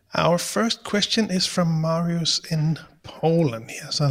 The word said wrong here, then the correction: Mariusz: And it's Mariusz, I'm sure.